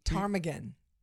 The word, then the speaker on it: ptarmigan: And then ptarmigan. – Ptarmigan, by the way. – Yeah. Another silent P.